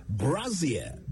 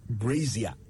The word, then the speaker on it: brassiere: The word is brassiere. Brassiere, B-R-A-S-S-I-E-R-E, brassiere, not brazier.